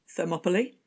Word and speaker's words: Thermopylae: Thermopylae.